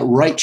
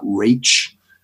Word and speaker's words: Raich: What could be Filburn is episode 5, and then that Reich case, but, and it's spelled R-A-I-C-H, but I think it's pronounced Reich and not Rach.